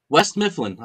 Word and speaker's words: West Mifflin: Wait, did you say that properly or did you? – West Mifflin. I said it properly.